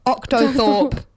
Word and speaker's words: octothorpe: You've been saying it wrong. It's octothorpe.